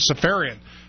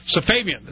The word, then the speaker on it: Safavian: He's the DNC press secretary, and he's talking about this whole thing with David Safarian, or however you pronounce his name. It's spelled S. Safavian. It's spelled S-A-F-A-V-I-A-N.